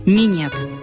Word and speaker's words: минет: В своем русском варианте слово пишется и произносится без мягкого знака «минет».